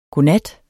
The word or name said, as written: godnat